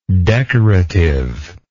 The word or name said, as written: decorativeness